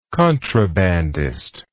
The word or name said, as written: contrabandist